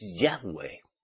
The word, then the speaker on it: Yahweh: No se puede leer, ¿verdad? YHWH. Porque el nombre verdadero es Yahweh.